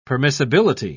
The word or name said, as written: permissibility